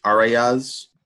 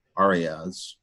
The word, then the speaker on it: Arraez: I guess we could just check in with Arraez. – Let's go with that. – Sure. – But I think it's Arraez. I think that's how you pronounce it. It's A-R-R-A-E-Z.